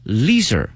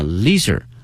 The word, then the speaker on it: leisure: 哎，这里面悠闲的生活啊，我们可以用这个 leisure 这个词， L-E-I-S-U-R-E 表示悠闲的这个词啊，有两个发音，一个呢是 leisure 啊 leisure，另外一个发音呢是 leisure 都可以，但是呢作为形容词的话呢后面加一个 ly 啊 leisurely life leisurely life 表示过于悠闲的哈，一天没有什么事儿可干哈，那么这种生活方式叫 leisurely life。